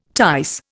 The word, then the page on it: tice: Indian